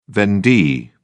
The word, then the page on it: vendee: British English